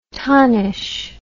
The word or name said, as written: tonnish